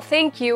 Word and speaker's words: Thank you: 何度か何度か発音をお伝えしてきてますけれども、 Thank you は TH。上の歯と下の歯の間に舌を挟んで、 th, th, th。